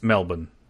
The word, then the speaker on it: Melbourne: I've always heard it from Australians as Melbourne. – Yes, it's Melbourne. Are you a douchebag if you say Melbourne and you're not Australian? – I think if you say it to Australians, no. I have maybe a controversial opinion on this. I think Australians are douchebags for insisting on the pronunciation of Melbourne. Because it's only Melbourne because that's how their accent pronounces the second syllable.